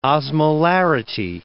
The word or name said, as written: osmolarity